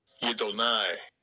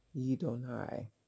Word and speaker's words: Yidonai: Yidonai. – Yidonai. Just like how Adonai has that same sort of ending on it, Yidonai has that ending on it.